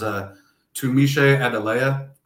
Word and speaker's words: Tunmise Adeleye: And then the third guy is Tumiche Adeleye, transfer from Texas A&M. Folks were really excited about in the spring. – Hold timeout. Great pronunciation, by the way.